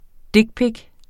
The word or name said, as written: dickpic